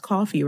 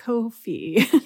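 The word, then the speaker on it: Ko-fi: So please, if you want to check that out, it's available on, because I don't want to butcher this, but it is pronounced coffee, right? – Oh my God, I have no idea. Oh, yeah. It's like Kofi.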